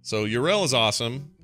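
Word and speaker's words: Yrel: We had a big argument on Core whether you're supposed to pronounce her name Yurel or not, but that's how Blizzard pronounces it, so F off, everybody. That's how they say it.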